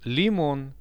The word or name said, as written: limon